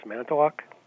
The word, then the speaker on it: Manitowoc: And Greg in Manitowoc, Wisconsin. Am I saying it right? – Yes, Manitowoc.